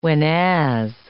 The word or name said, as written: whenas